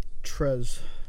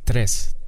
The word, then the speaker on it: tres: Yeah, that wasn't the correct, No? – Pronunciation. – Damn. Life Return really of, be life, bro. – Yeah, just do, like, tres. Tres.